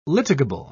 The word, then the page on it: litigable: British English